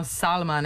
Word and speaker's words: Salma: Da, kao što govorimo Salma, ne se Selma Hayek, recimo.